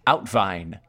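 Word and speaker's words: uitwaaien: Outvine. It's spelled U-I-T-W-A-A-I-E-N. U-I-T-W-A-A-I-E-N. It's the Dutch practice, it's pronounced outvine,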